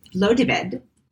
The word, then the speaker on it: Blodeuwedd: But it is a Welsh goddess of spring named, and we're going to have to spell this, but it's Blodeved. And it is spelled Blodeuwedd, and it is Welsh.